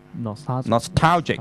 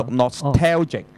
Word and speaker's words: nostalgic: Nostalgic 嗱，你唔好讀 T A L，其實好多發音，因為個 A 字係最麻煩嘅，佢 A 字可以好多個發音噶嘛。咁所以佢呢度咧就唔係讀 nostalgic 嚇，或者唔係讀 nostalgic，係 nostalgic。